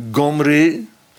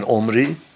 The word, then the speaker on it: Omri: En Omri heet daar Gomri. Maar als die ayin wegvalt, dat is naar het gebruikelijke moderne Hebreeuws, ook Bijbels Hebreeuws zoals men het opvat, daar spreek je de ayin niet eens meer uit. Sla je gewoon over. Dus dan wordt het, omdat er toch een klinker moet komen, schrijft men Omri.